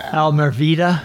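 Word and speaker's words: Almereyda: One other thing I want to talk about, and that's the director, Michael, how you pronounce that last name? – Almeida.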